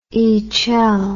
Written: icel

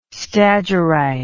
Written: stagyrite

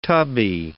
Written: tubby

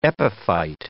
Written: epiphyte

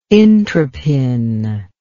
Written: intropin

